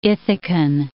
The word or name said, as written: Ithacan